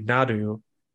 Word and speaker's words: Naru: So Trachtenberg said on social media that Comanche actually pronounced their R's as D's. So Naru would be Nadu, and Sari, the dog, would be Sadi.